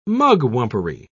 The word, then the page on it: mugwumpery: British English